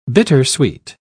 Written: bittersweet

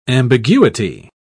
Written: ambiguity

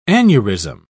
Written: aneurysm

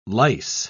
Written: lice

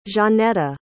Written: Zaneta

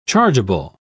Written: chargeable

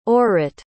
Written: orit

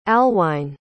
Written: alwine

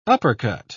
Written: uppercut

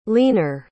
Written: leaner

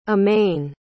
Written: immane

